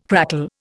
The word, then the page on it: crackle: Indian